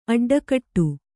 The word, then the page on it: ಅಡ್ಡಕಟ್ಟು: kannada